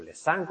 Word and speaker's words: Lessing: Aquí tenéis otro autor que sale bastante, que es Lessing, o Lessing, como se pronuncia, conocido por su obra Laoconte, ensayo sobre los límites entre pintura y poesía.